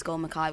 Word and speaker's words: Mackay: I'm on Instagram at G Mackay, so that's GEE _ Mackay, which is spelled MACKAY, and that's also the same name as my Twitter handle as well.